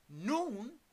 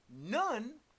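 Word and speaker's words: Nun: Noon is the father of Joshua. But we have been saying none all along, but it's noon.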